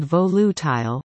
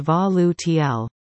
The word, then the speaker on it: volatile: The word volatile is pronounced differently in English and American English. In English, it is said volutile, with the stress on the first syllable and the last syllable making use of a long I in tile.